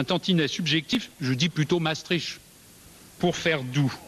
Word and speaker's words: Maastricht: Ceux qui sont plutôt pour disent, même si ce n'est pas à mon avis la prononciation exacte, Maastricht. C'est doux, c'est musical. Ça entraîne, ça ne fait pas peur. Et ceux qui sont plutôt contre disent plutôt Maastricht.